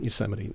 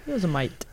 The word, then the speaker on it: Yosemite: for those who don't know, it's a beautiful place within Yosemite National Park.